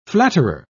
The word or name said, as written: flatterer